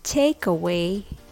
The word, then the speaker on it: takeaway: Do you hear the difference between takeaway and take away? It's the intonation ta da ta or ta da ta. Just remember when the first syllable is stressed, it's a noun.